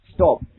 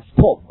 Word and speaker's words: stop: 这个 stop，没有人喊 stop 吧？对不对？总是读的是 stop。